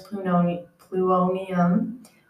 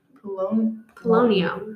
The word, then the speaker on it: Polonium: Marie named one of the elements plutonium, after her homeland, Poland. – Polonium? – I don't know how to say it. – No, fine. – Polonium.